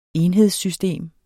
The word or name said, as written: enhedssystem